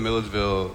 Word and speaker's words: Milledgeville: How far is that from the town I can't pronounce, Midgerville or whatever? – Milledgeville. – So Milledgeville.